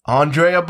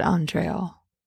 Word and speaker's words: Andrea: How else would you pronounce A-N-D-R-E-A? – I always said Andreal. – Andreal? No.